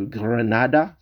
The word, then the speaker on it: Granada: Real Madrid est premier, alors que Granada, c'est comme ça que ça se prononce en espagnol, est 19e actuellement du championnat d'Espagne.